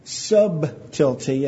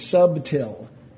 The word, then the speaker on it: subtil: But I fear lest by any means, as the serpent beguiled Eve through his subtlety," and yes, that's how you pronounce it. It's not subtle, which is T-L-E, it's subtil, and it means underhanded.